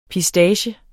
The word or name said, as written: PISTACIER